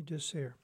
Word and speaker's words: DeSare: His last name is spelled D-E-S-A-R-E. Tony DeSere.